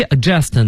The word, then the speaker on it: adjust: 对，所以是 adjust 呢，它前面一个 D 啊，大家就不发音了，但 AD 呢是个强调，那就是哎让你变得特别的正确，所以就是我们说的校正校对。